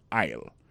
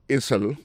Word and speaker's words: aisle: aisle li marqué esel